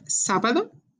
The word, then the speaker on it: sábado: Remember, the accent is on the first part of the word, sábado.